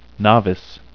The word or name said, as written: novice